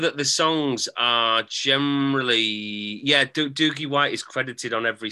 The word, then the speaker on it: Doogie: One, I need you to stop saying Doogie. – Apparently that's how he pronounces it. Because I've heard him in interviews and that's how he says it.